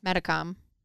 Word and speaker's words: Metacom: So when they went, you say Metacom? – Metacom. – How do you spell that? – M-E-T-A-C-O-M.